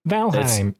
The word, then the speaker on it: Valheim: I mean, anytime EI is in a word that's not from the English language, it's pronounced Heim. – Valheim.